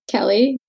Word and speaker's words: Kelly: Obviously, my name is Kelly, K-E-L-L-Y. So I get either, like I told you, either the C-E-L-Y or a K-E-L-Y.